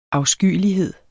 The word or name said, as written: afskyelighed